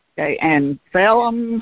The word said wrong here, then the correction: psyllium: husk powder. I can't pronounce that right, I'm sure. P-S-Y-L-L-I-U-M, husk powder. I see that in a lot of recipes. – I didn't hear your spelling of the first part. Can you spell it again? – Yeah, P-S-Y-L-L-U-M. Husk. – Oh, psyllium husk.